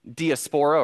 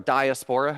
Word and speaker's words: diaspora: speak of the Jewish diaspora or diaspora, however you prefer to pronounce that,